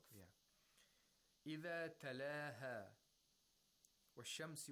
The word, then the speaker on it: الشَّمْس: So we have to pronounce the sheen with tashdeed without pronouncing the lam sakina. وَالشَّمْس.